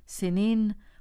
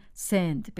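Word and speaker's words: سنّ: حرف نون در آخر این کلمه با تشدید است، یعنی باید آن را سن تلفظ کرد نه سن. همین تلفظ دشوار با تشدید باعث شده که بسیاری ها از روی سهل‌انگاری آن را به کلمه‌ای که راحت تر تلفظ می شود تبدیل کنند، یعنی سند بگویند. ولی شما احتمالاً نمی خواهید چون این سهل‌انگار باشید. پس بکوشیم آن را به صورت درست سن تلفظ کنیم و به خاطر داشته باشیم که سند در اصل نام رودی است در پاکستان که دریای کابل به آن می ریزد.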